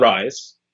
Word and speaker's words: Rhise: And this is about a young man named Leon Rhise. The H is silent, of course.